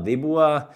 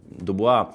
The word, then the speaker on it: Dubois: Nu, Dubois, jā, interesanti, ir redzēts arī mēdījos, ka viņi izrunā Dubois. Šīs uzvārdu spēles, Tā laikam arī būtu pavisam cita tēma, bet nu, Dubuā.